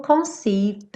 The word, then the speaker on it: conceived: Londi, it could have not been done without you who conceived the one. Aqui o conceived, a gente não pronuncia o E e terminaria com o D, consoante oclusiva, e ele já emenda no the one.